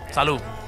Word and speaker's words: salud: It's a silent D. – If you say D. – it fast, the T and the D can sound the same. Exactly. – It's a D, but the thing is that it's like, you know when you pronounce a letter D and you release the tongue at the end? When you say salud, you stop. The tongue stays.